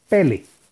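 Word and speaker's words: Pele (פלא): que son las letras Pei Lamet Aleph y que se pronuncia Pele.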